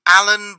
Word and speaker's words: Allan: Alan Brown. – Alan, is that A-L-A-N? – No, A-L-L-A-N. – A-L-L-A-N? – And Brown has an E.